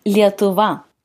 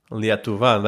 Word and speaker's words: Lietuva: How do you pronounce Lithuania in Lithuanian? – Lietuva. – Lietuva.